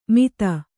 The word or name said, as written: ಮಿಥ